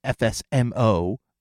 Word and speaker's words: FSMO: And those five FSMO roles that I've talked about, and I've linked them with Wikipedia on the definition of the FSMO, the FSMO, which is pronounced FSMO.